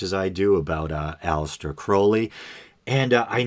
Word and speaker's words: Crowley: And yes, Crowley is the correct pronunciation.